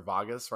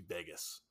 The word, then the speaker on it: Vegas: But Ben has this accent where he pronounces things with a V in it, like, incorrectly. So he's saying, like, Vegas or Vagas right now, and it should be Vegas.